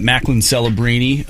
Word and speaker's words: Macklin Celebrini: Yeah, so one of the big things of today is that Macklin Celebrini of the San Jose Sharks, he signed a five-year, $94 million contract. – Thank you for saying that right.